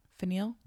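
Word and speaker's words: Faneuil: Finial? – Yes, thank you, Finial Hall.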